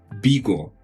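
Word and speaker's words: Vigo: Она произносится, вот, например, мой город называется Биго. То есть у них нет такого четкого произношения «б», да, то есть это тоже нужно учитывать. То есть это между «б» и «в», как бы «би».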